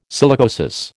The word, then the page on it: silicosis: American English